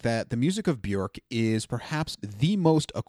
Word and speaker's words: Björk: First off, yes, her name is pronounced Björk, not Bjork, so you can say that properly now because I didn't for a very long time.